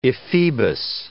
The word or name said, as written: ephebus